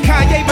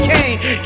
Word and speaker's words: Kanye: All I need is y'all to pronounce my name. It's Kanye, but some of my plaques, he still say Kane.